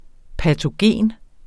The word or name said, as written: patogen